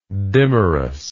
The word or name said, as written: dimerism